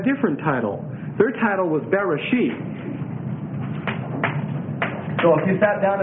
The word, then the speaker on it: Bereshith: That's spelled B-E-R-E-S-H-I-T-H. And what this word Bereshit means is in the beginning. Bereshit, excuse me. S, thank you. Like that. B-E-R-E-S-H-I-T-H.